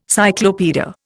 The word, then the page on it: cyclopaedia: Indian